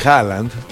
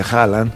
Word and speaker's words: Haaland: Y por otro lado, Erling Haaland, que los ya me enteré porque entrevistaron a un noruego, ¿cómo se pronuncia Haaland? Ves que tiene doble A, ¿no? – ¿Haaland? – No, Haaland. – Haaland. – Haaland. Ellos es Erling Haaland. Bueno, Haaland. Para nosotros es Haaland.